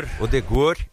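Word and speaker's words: Ødegaard: Eu acho que esse time da Noruega tem o seguinte, eles têm dois grandes nomes, né, o Odegaard, como é que fala Odegaard mesmo? Odegor, é assim? – Odegor e o Haaland, né, mas fora isso também, a Nusa e o Sørloth é um time bem comum, viu?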